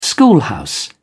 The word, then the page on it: schoolhouse: British English